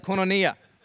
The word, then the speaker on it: koinonia: See, the word fellowship um, is derived from the Greek word, how do you say it? That's right, just like that, koinonia.